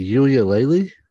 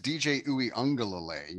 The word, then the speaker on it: Uiagalelei: is it Uiulele? – That's That's a pretty common wrong answer. – Yeah, that's chalk. Give him the 411 though. – It is DJ Uiagalelei.